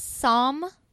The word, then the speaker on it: Psalm: His name is Sam West. – Yep. The P is silent. – The P is silent. So it's Psalm, like the book of Psalms from the Bible, just in case you're not familiar.